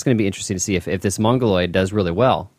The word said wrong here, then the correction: Mongoliad: Or did I say that right? My goodness. – Oh, Mongoliad.